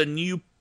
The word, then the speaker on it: GNU: And it's published under the new general public license. New, as in GNU, but pronounced new.